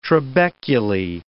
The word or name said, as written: trabeculae